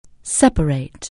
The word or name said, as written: separate